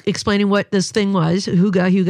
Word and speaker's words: hygge: H-Y-G-G-E. Yeah. I always, to be honest, say hygge, but I think the real pronunciation is hygge.